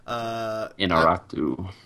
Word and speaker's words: Iñárritu: Iñárritu.